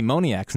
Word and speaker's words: Moniak: Moniak's name. – Ooh. – And before Fransky could even answer, Al Kaline chimes in and says it's Moniak.